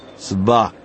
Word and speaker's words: Sabacc: he asked, mispronouncing it Sabacc. – "Sabacc," Lando corrected, gathering the cards.